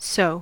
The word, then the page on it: sow: /saʊ/; /səʊ/; /soʊ/